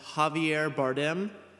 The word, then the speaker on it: Javier Bardem: Yevyar Bardem. – Quick editor's note, now that I actually have the name in front of me, it is pronounced Javier Bardem.